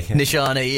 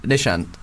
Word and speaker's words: Nishant: Nishani, yeah. Joel likes to vary up whatever the second syllable of my name is. – Nishius. – No, it's Nishant.